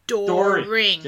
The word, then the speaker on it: Deering: During? Is it during? Like, during door. – The session? – Door. It's German. Door. Ring.